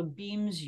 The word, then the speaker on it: Beamz: The first thing we started using is called a BEAMS unit. It's spelled BEAMZ.